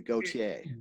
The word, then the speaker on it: Gaultier: So it's kind of like it's not a soft T, but it's like a middle of the road T. Like it's not a goat. Yeah, it's a Gaultier.